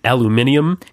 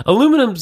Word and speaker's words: aluminium: That's a more correct pronunciation. – Yeah, it is. Aluminum sounds kind of like you're a bit of a doof.